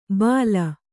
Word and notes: ಬಾಲ: Kannada

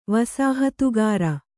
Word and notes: ವಸಾಹತುಗಾರ: kannada

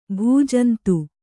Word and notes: ಭೂಜಂತು: Kannada

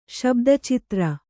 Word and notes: ಶಬ್ದಚಿತ್ರ: śabda citra

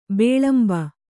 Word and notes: ಬಿಲಿಂಬಿ: Kannada

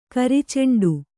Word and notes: ಕರಿಚೆಂಡು: kari ceṇḍu

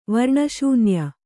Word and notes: ವರ್ಣಶೂನ್ಯ: varṇa śunya

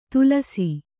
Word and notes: ತುಲಸಿ: Kannada

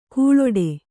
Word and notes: ಘೀಳಿಡು: kannada

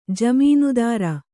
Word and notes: ಜಾಮೀನ್ದಾರ: kannada

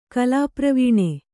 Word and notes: ಕಲಾಪ್ರವೀಣ: Kannada

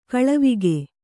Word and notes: ಗೆಳೆವಕ್ಕಿ: kannada

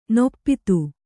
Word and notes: ನೊಪ್ಪಿತು: noppitu

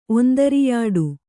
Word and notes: ಒಂದರಿಯಾಡು: ondariyāḍu

